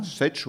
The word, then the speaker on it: Sesh: Vous avez alors les dessinateurs, je vais les citer quand même, parce que c'est important, Efa et Sesh, qu'on prononce comme ça ? – Sesh, ouais. – Sesh, on dit Sesh.